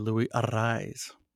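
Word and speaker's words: Luis Arráez: He does the rolling of the R's with a double R. So when they announce him, they say Louis Arraez. So that's the right way to pronounce his name.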